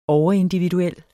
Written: overindividuel

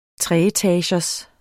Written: treetagers

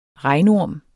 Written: regnorm

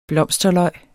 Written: blomsterløg

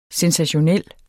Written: SENSATIONELLE

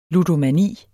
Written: ludomani

